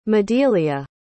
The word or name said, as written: Madelia